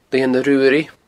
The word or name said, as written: Lianag Ruaraidh